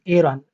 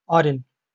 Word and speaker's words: Aaron: And as I was recently informed, Nabi Harun, his name is pronounced in the anglicized form as Aaron, not Arun.